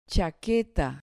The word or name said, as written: chaqueta